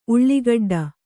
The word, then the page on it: ಉಳ್ಳಾಗೆಡ್ಡೆ: uḷḷāgeḍḍe